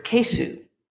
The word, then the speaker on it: kesu: So what people were actually saying was the Japanese word kesu, which in the English alphabet would be spelled like K-E-S-U or K-E-I-S-U. Yeah. It's just that when you say it in Japanese, you hardly pronounce that U. It becomes almost silent, or in some cases actually silent.